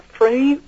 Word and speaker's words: Freund: I'll pronounce it friend. It's F-R-E-U-N-D. Or Freund.